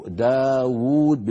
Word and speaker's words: داود: يكتبون واو واحدة وينطقونها واوان، ينطقونها واوين، داود بوزن فاعول، انتبهنا؟